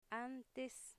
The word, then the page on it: antes: 'antes